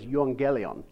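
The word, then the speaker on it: euangelion (εὐαγγέλιον): Okay, evangelism is actually an English pronunciation of a Greek word. The Greek word is euangelion, and it just means good news. It's literally two words, like euphemism or eulogy.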